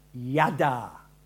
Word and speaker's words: yada: You know, there's an Hebrew word and it's pronounced yada. Y-A-D-A-R. Yada.